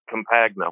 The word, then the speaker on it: Campagna: What's funny is that you worked that hard on it when it's been in this country long enough, we've slaughtered it, and it's Campagna. We don't even pronounce it properly ourselves.